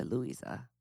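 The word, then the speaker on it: Luisa: It actually is probably... I think it's actually a Louisa. – A Louisa? – Louisa. – Louisa. – Louisa. Like Louise, but not Louisa.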